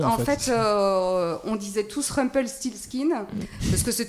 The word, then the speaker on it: Rumpelstiltskin: C'est vrai, Rumpel Siskin. – Ah, non, il y a un T qui manque, c'est Rumpel Stilt. – Ah, voilà, Rumpelstiltskin. Et voilà.